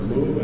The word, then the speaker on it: Mu: Yeah, Mu, that's where you pronounce it. Mu, as in mood.